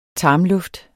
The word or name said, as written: tarmluft